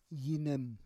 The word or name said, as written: yinem